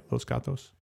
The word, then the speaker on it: Los Gatos: Am I saying that right? Los Gatos? – Yeah, Los Gatos.